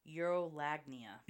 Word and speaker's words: urolagnia: Urolagnia.